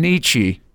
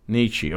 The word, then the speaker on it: Nietzsche: Can you please say it again? – Nietzsche. – Nietzsche. Okay, obviously. – Rhymes with lychee.